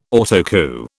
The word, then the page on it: Autocue: British English